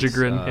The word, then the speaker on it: Liljegren: Liljegren, yeah.